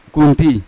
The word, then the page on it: gunti: Yawa